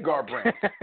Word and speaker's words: Garbrandt: No, I was listening to y'all pronounce the name Garbrandt. And I was like, it's just like Rembrandt, only with a G-A-R in front of it, y'all. – I said Garbrandt.